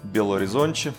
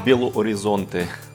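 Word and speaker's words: Belo Horizonte: Да, привет из э, города Белоризонче или Белуоризонте с русским произношением.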